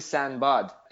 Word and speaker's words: Sinbad: And what's funny is, on the set, I essentially insisted everyone say it correctly, which is actually Sinbad.